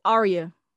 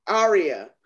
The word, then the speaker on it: Aria: My guest, now, do I pronounce it Aria? – Aria. – Aria.